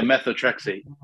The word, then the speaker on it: methotrexate: She was denied to a refill for Metatristate. I'm not sure if I'm pronouncing the medication correctly, but it's medication for rheumatoid arthritis and osteoporosis. So. – Oh yeah, methotrexate.